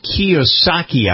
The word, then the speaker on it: Kiyosaki: It's Kiyosaki, I believe.